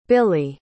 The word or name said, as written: billye